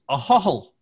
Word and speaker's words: Ahoghill: So I live in a little village called Ahohl. It's spelled Ahoghill, and I live out in the sticks in the middle of nowhere.